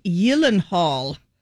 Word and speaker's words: Gyllenhaal: But it's Swedish. So no. It should be pronounced Gyllenhaal. Jake says, quote, the only two places it's pronounced correctly is in Sweden and at IKEA.